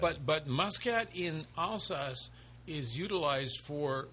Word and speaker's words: Muscat: Muscat. – Muscat. – Which they pronounce Musca.